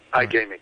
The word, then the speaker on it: IGamiX: Have I got the pronunciation of the company name correct? Is IGamiX? – Yes, that's right. – IGamiX.